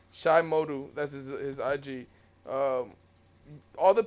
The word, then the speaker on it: Modu: Well, how do you pronounce his last name? It's Shy. – Shy Modu. – Modu, right? Modu. Yeah, I'll say Modu.